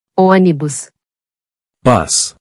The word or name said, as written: Onibus